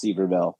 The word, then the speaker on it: Sevierville: I think it's pronounced Sevierville, uh, and it's called Parkway, and it's uh, you know it's you know bigger bigger highway in some places than others.